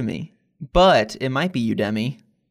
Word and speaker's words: Udemy: So let's dig into Udemy, as you said, although I think it's Udemy, right? Have you been saying it wrong your whole life? – I always say Udemy. – Well, see, in my head, it was Udemy, like you to me, right?